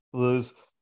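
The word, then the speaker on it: Gluz: Glues.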